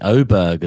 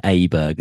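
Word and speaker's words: Åberg: And I've got to say, Oberg as well, which I've clarified this week, that is close to the correct pronunciation than Aberg, as I was calling him last year at the Ryder Cup.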